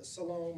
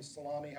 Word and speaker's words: Salome: So we've got three Marys, one of Mary's sisters, who we think is Salome, or Salami, however you pronounce it. And don't pronounce it that way. It's insulting.